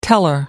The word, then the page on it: teller: American English